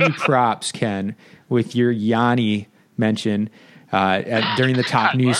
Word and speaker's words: Giannis: Where he's from in Greece, they don't pronounce the S, and so they pronounce it Yanni.